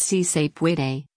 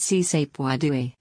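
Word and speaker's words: Sí se puede: It's C-Sape-Way-Day, yes you slash we can, not C-Sape-Way-Dui, tweeted Deputy Communications Director for the Republican Governors Association Joanna Rodriguez.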